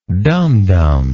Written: dumb dumb

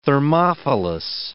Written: thermophilous

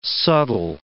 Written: subtle

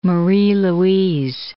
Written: Marie-Louise

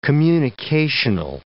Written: communicational

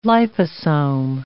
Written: liposome

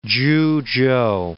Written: Chuchow

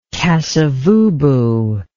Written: kasavubu

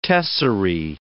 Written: tesserae

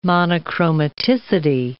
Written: monochromaticity

